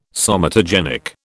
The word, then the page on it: somatogenic: British English